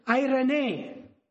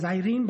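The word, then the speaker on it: eirene: The Greek word, I believe, for peace is, I'm sure we know this, irene. I like to pronounce it as Irene, but the proper pronunciation, I believe, is irene.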